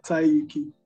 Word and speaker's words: Tyyuki: Yep, Tyyuki.